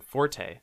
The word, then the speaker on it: forte: heard people complain, or I've heard a complaint about the word forte. – Mm-hmm. – Forte is originally supposed to be pronounced fort. – Mm-hmm. – That was the original pronunciation.